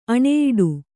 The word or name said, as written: ಆಣೆಯಿಡು